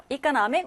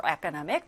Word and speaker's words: economic: economic or economic 둘다 발음 가능할 것 같아요.